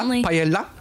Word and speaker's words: paella: How do you say la paella? – If you're Spanish, you don't pronounce the ools, you pronounce it as a yay.